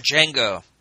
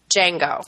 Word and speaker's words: Django: How do you say it? – Django. – Django. – The D is silent.